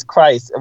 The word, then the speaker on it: Kryst: And it's ironic that her name is Christ, but it's spelled K-R-Y-S-T.